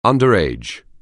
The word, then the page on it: underage: anglais